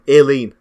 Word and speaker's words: Aileen: Is that not an alien? – No, Aileen. That's how you pronounce it.